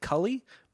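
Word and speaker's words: Kali: I think it's pronounced, they pronounce it Cully, but it's K-A-L-I, and everyone says Callie.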